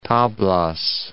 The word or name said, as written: Tablas